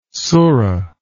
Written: sura

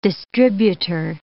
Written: distributor

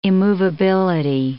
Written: immovability

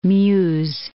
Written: Meuse

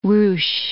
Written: ruche

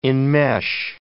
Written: enmesh

